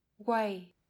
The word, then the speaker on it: weigh: Chữ ghép này có một số cách đọc khác nhau nhưng trong từ way, GH tạo thành âm câm nên chúng ta đọc là way.